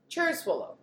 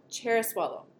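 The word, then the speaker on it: Cerasuolo: Cherasuolo. – Cherasuolo. – The Abruzzo. – I always want to say Sarah because it's C-E-R-A at the beginning.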